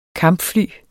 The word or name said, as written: kampfly